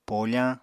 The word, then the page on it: pofañ: Apatani